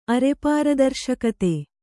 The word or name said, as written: ಅರೆಪಾರದರ್ಶಕತೆ